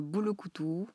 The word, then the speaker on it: boulokoutou: Euh, je t'ai pas ramené le sachet du thé que tu bois là, mais c'est le boulokoutou, euh, ça s'écrit avec un U. Euh, on est des bantous. En principe, on a des lettres qui n'existent pas chez nous, comme le C qui n'existe pas. On appelle d'ailleurs au Congo, on appelle le C français. Et si tu regardes bien dans les mots, il n'y a pas de mot que tu écris avec un C. Le U se prononce ou